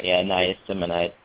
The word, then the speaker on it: niacinamide: Niacinamide.